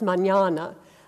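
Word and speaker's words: mañana: So what we see here with Draliana is that she's written Maniana there with the IA instead of the tilde mark over the N to signal that it should be pronounced Maniana, and written like that.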